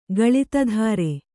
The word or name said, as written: ಗಳಿತಧಾರೆ